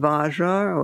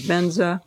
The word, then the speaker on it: Vajra: Om Ahung can be spelled H-U-M or H-U-N-G, Om Ahung. And then Vajra, or pronounced by Tibetans, Benza, V-A-J-R-A, Vajra.